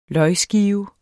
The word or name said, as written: løgskive